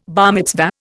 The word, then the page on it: bar mitzvah: Indian